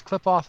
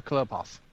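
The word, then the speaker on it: Qliphoth: It's Klipoth or Klipoth. So it's a reference to the Sephiroth.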